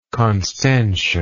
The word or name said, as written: constantia